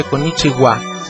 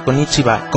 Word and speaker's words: Konnichiwa: Para empezar, no se dice Konnichiwa, sino Konnichiwa.